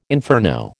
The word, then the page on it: inferno: American English